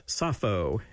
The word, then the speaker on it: Sappho: And the title refers to the ancient lyric poet Sappho, as we say her name in English, who came from the Greek island of Lesbos, and who impressed Xenakis by including rhythmic shifts in her poetry.